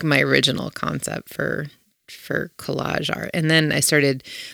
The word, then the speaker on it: Kolaj: So I went to some shows of different collage artists. There's magazines that are specific to collage art out there. There's one that's called Collage, but it's spelled K-O-L-A-J.